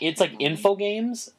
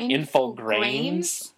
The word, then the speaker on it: Infogrames: It's like Infogames, but with an R in there. So it's like Infogrames.